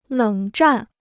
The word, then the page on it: 冷战: lěng zhan